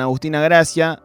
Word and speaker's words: Agustina Gracia: Estamos hablando con Agustina Gracia,